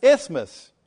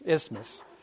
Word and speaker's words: Isthmus: Isthmus. All right. It's not Christmas. Isthmus. Isthmus. It says T-H. You guys get it.